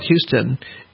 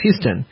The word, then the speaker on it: Houston: Well, in New York, there is there's the Houston Street Station, which is spelled Houston. And in Houston, in Texas, it's pronounced Houston.